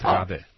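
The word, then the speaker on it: Raabe: Rabe.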